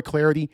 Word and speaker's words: Clardy: At Troy Clarity. At Troy Clarity. Last name is spelled C-L-A-R-D-Y.